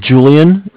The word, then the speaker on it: Julian: My name is Julian. It's J-U-L-I-A-N. Last name Martinez. M-A-R-T-I-N-E-Z.